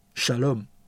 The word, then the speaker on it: shalom: 和平在希伯来文是 shalom，读音的翻译是沙龙，而这个词语大部分的时候都被翻译为平安。